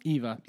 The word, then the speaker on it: Eva: Uh, I always say her name wrong, but Ava? Eva. Eva. I, I, Eva.